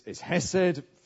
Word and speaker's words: hesed: The Hebrew word is hesed, that's how you pronounce it.